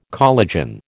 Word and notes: collagen: American English

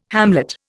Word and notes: hamlet: Indian

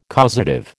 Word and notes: causative: American English

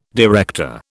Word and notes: director: British English

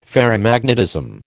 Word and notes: Ferrimagnetism: American English

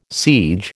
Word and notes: siege: American English

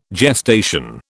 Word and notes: gestation: British English

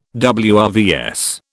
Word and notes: WRVS: British English

